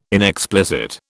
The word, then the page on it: inexplicit: British English